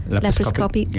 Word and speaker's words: laparoscopy: Laparoscopy.